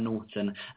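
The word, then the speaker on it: Naughton: That's Aaron Norton, A-A-R-O-N. Then my surname is spelled N-A-U-G-H-T-O-N. A bit like naughty, but not, because I am ever so nice, honest.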